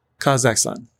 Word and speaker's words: Kazakhstan: Say it in syllables. – Kazakhstan, right? – Kazakhstan. – Yeah, you just put the emphasis on the last.